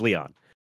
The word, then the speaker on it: Leon: And I'm making sure to say his name is just Leon is what it is. It's Leon.